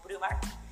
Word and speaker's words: Brewmart: En Instagram estamos como Brewmart-Perú, o sea, se escribe P grande R E W M A R T, ¿no? Brewmart, o Brewmart.